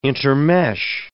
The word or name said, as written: intermesh